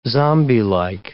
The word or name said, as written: zombielike